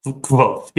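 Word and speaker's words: Kvothe: But there is also a very similar scene in which, is it Quoth? Is that how you pronounce his name? – Quoth, yep.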